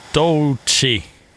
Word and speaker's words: Doechii: Runway med Lady Gaga och Doechii. Mm. – Ja, det var korrekt. Jättebra uttalat.